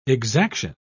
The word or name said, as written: exaction